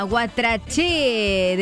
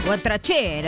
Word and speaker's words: Guatraché: Guatraché de La Pampa, acento en la E. Guatraché, la localidad más cercana de la colonia menonita, situada en el interior del campo pampeano.